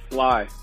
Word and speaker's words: Slay: Is it Slay or Sly? – It's Sly. Brandon Sly.